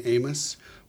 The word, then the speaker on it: AiMOS: So uh, we pronounce the name of the machine AiMOS, but that owes back to the first founding professor here at Rensselaer, Amos Eaton.